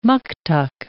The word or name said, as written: muktuk